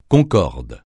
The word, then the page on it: concorde: francais